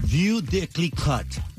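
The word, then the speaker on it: Veuve Clicquot: Y entonces, eh, nosotros no lo pronunciamos bien, pero JJ de Mega TV sí, ¿cómo es que se pronuncia? – View de Clicot.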